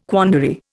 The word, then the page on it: quandary: Indian